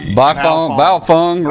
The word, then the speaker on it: Baofeng: They took a biphone. How do you pronounce that? The B-A-O-F-E-N-G. – Baofeng radio. – Baofeng. Yeah.